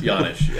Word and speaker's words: Janish: Janish, yeah.